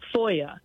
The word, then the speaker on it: FOIA: That's called Freedom of Information Act, or FOIA, is how I would pronounce it.